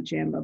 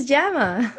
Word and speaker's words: JAMA: So, um, you know, I think that the way that that JAMA, uh, and I learned that it's pronounced JAMA, not jamba, by this podcast, by the way.